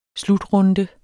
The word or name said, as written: slutrunde